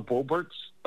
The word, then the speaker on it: Boebert: So, you know, we have Lauren Boebert's, that's how you pronounce the name, seat.